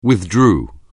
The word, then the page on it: withdraw: anglais